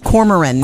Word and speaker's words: Cormoran: Lee wants to actually thank you, for turning him on to the Corman B. Strike books. – Yes. – Or I don't think I said that right. – Cormoran. – Cormoran. – I think that's Cormoran. Okay, I don't know what to call it.